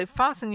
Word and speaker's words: fasten: But if you have something like fasten, so fasten your seatbelts, it's not fasten your seatbelts, it's fasten your seatbelt.